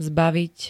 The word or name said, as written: zbaviť